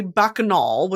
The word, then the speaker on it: bacchanal: Okay, so it's this concept through a bacchanal, which let me double check that that's bacchanal pronunciation.